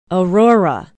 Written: aurora

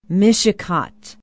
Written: mishicot

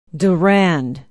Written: durand

